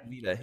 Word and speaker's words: Sevilla: Ozz, I think it's Sevilla. Is that the right way to say it? – Sevilla, yeah. – Sevilla, yes.